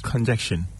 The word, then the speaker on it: congestion: Can what? – Conjection. – Congestion. – Yeah. – Congestion. – Chin. Congestion. – As a chin is work of heroin. – As a confounding. Oh, yes. If you want to say it right, congestion.